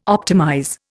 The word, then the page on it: optimize: Indian